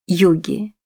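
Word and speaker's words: Huger: Эта гугенотская фамилия произносилась как «Юги».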